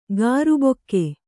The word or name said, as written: ಕರ್ಬಕ್ಕಿ